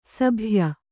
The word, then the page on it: ಸಭಯ: sabhaya